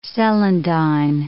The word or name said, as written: celandine